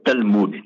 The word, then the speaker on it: Talmud: Or they pronounce it Talmud, T-A-L-M-U-D.